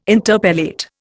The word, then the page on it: interpellate: Indian